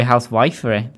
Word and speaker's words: housewifery: I would have thought it'd be housewifery, but is it not? – That's not how you pronounce it.